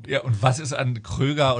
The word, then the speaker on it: Kroeger: It's Kroeger. Don't trust the internet.